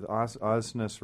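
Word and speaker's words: Aasness: Rhymes with Ozness rhymes with awesomeness. That's how I can get people to pronounce it correctly. – Let's not even talk about what mine rhymes with. – N-E-S-S.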